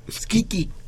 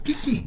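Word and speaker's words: Schicchi: En primer lugar, me entero que el, área de Puccini de Gianni Schicchia es, no, no, eh, es, es Chiquia. ¿no? – Skiki. – Skiki. Es es, ¿Qué, qué, que haga? – Kiki, es que no sé cómo se pronuncia eso. – Sí, así es Kiki.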